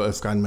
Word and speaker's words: Erskine: Is that sort of northeast pronunciation of Erskine? – Erskine. Yes, Erskine. – Erskine Antiques. There's an Erskine... I know this because there's an Erskine Antiques in South Shields, and it's definitely called Erskine Antiques, and it's on Erskine Road, and that road is pronounced Erskine Road.